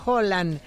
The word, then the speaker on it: Haaland: Ya que hablan de pronunciación, Haaland se pronuncia Holland, pero ya todos le dicen Haaland, ya fuera de Escandinavia.